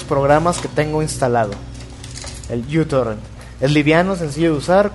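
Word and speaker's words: uTorrent (µTorrent): La primera letra es una letra griega, ¿sí? – Sí, una MIU. – Se pronuncia uTorrent.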